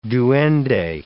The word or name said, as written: duende